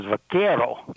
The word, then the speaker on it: vaquero: Incidentally, the pronunciation of that is vaquero.